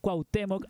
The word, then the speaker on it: Cuauhtémoc: Y bueno, no está de más decir, por favor, que vayan a escuchar el trabajo de Simón Campuzano Brillo y el disco Cuauhtémoc. Ahora sí que lo dije bien.